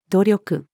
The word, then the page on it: 努力: female